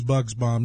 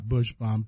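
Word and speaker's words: Buchsbaum: And it's Bugsbaum, not Bushbaum. People think I'm mispronouncing it,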